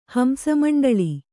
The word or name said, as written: ಹಂಸಮಂಡಳಿ